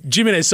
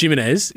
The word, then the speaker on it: Jimenez: It's Jimenez. So Jimenez. I say it Jimenez. Yeah, if Jimenez. You want to say it the real Spanish sort of way, you'd say Jimenez.